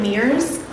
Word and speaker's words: Mears: My name is Kimberly Mears. Last name is spelled M-E-A-R-S.